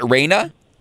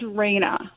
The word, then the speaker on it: Raina: Is it Reina? – Yes, Rainbow Bridge Reina.